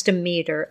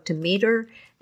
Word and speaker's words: Demeter: In this podcast, you'll hear me pronounce the goddess Demeter as both Demeter and Demeter. In Greek, the word would be Themeter. In English, it's most commonly Demeter. But in the group I told this story to, they pronounced her name Demeter.